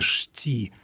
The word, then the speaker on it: щи: Между прочим, напомню, что в диалектах слово «щи» произносилось с двумя согласными «шти». «Шти». И это ближе к исконному состоянию этого слова.